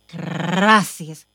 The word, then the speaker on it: Gracias: Gracious. – No, say it right. Gracias. Gracias.